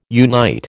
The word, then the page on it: unite: American English